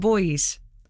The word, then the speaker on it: buoys: Girls and boys, but it's spelt B-U-O-Y-S.